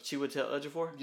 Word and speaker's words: Chiwetel Ejiofor: From Chiwetel Ejiofor? – Yeah, there you go.